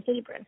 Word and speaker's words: Hebron: And Atchison, Kansas, and Hebron, Kentucky. Is that how you say that? – Hebron, okay.